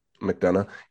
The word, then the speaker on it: McDonough: I think that Neil McDonough, right? That's how you pronounce his name. McDonough.